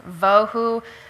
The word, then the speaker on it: bohu: Bohu actually is pronounced vohu.